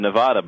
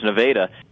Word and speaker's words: Nevada: Yeah, a lot of people have said Nevada, but no, it's Nevada.